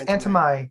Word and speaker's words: Antimai: So the new album, um, Antime. How do you say it? Is that Antimai? right? Is it Antime? I think it's Antemai because uh, he repeats it a couple times in the first track.